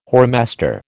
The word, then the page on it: whoremaster: American English